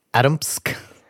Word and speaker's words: Atomsk: Atomsk. – Atomisk. – Atomisk. It's spelled Atom. Atomsk.